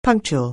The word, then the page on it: punctual: anglais